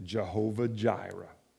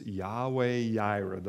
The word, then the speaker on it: Yahweh Yireh: You may hear people pronounce it Jehovah Jireh. But I want to encourage you, it is Yahweh Yireh, the Lord will provide.